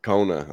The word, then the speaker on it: Kona: Kona.